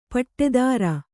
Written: ಪಡತರ

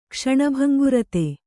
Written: ಕ್ಷಣಭಂಗುರತೆ

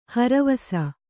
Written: ಹರಿವಾಸ

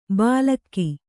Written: ಬಾಲಕ್ಕಿ

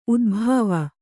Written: ಉದ್ಭಾವ

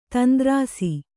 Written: ತಂದ್ರಾಸಿ

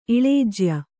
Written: ಇಳೇಜ್ಯ

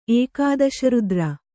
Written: ಏಕಾದಶರುದ್ರ

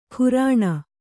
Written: ಘೂರ್ಣ

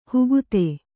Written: ಹುಗುತೆ

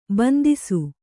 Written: ಬಂದಿಸು

